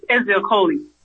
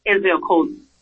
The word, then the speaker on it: Ezeokoli: Yes, Izakoli. E-Z-E-O-K-O-L-I. Izakoli.